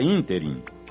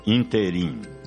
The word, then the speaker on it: ínterim: A palavra ínterim é proparoxítona e, portanto, com acento gráfico na antepenúltima sílaba, e não oxítona, como ouvimos por aí, ínterim.